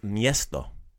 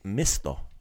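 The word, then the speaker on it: mjesto: So, the word for place is miesto. In the song, you'll hear it pronounced misto. Right? – Misto. – So, kind of maybe a little bit lazified it a little bit. Took out the mia and just put it m, like misto.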